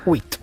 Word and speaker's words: huit: Huit. – ¿Qué? – Muy bien. – Huit.